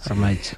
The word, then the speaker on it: Ormaetxe: Ormaeche.